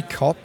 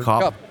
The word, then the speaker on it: Kaup: Michael, I don't know how to pronounce this. K-A-U-P, Kopp. – Michael Kopp.